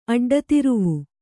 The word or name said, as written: ಅಡ್ಡತಿರುವು